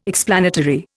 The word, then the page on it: explanatory: Indian